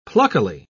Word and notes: pluckily: British English

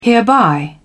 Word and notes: hereby: British English

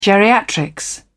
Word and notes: geriatrics: British English

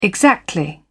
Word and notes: exactly: British English

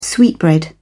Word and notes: sweetbread: British English

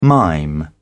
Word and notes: mime: British English

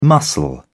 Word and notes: muscle: British English

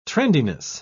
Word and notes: trendiness: British English

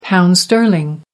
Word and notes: pound sterling: American English

